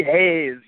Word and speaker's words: Hayes: Hayes of Chance Muay Thai Academy out of the RDU area in North Carolina.